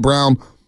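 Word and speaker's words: Braun: Spell Brown, but they pronounce it Brown.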